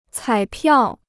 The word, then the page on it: 彩票: cǎi piào